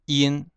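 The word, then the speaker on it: 他們: 第二行的你们，呃，你们，你们是念成 lin，lin，然后我我们我们就念成 gun，他们呢就念成 in，就在一的基础上加一个那个有点鼻音的样子 in，然后那个第三行男人就念成 double，女人就念成 double。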